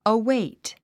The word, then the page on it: await: əwéit; アウェイト